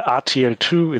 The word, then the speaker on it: RTL2: Well, RTL2, it's how we pronounce it in English, is one of the private TV stations in Germany.